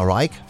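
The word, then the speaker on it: Rigg: Und dann möchte ich noch erinnern an Diana Rick. Ja, Diana Reich, vielmehr ausgesprochen, ne? Ist ja Englisch, okay.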